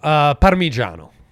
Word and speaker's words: Parmigiano: Parmigiano. – Okay, could you Americanize that for us a little bit? Not with the O on the end. – Parmigiano.